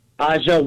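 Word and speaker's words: A'ja: Yeah, it's not Angel Wilson. It's Aja.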